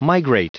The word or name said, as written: migrate